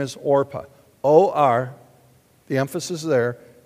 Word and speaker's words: Orpah: Her name is Orpah. Now, have you ever heard of Oprah? Oprah's name is spelled similar to Orpah, just a little bit different. It's almost Orpah.